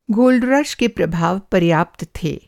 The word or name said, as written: गोल्ड रश